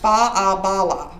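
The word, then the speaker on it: Faugh a Ballagh: There was a Gaelic saying that they would say, and I believe it's pronounced fa-a-bala, which means clear the way.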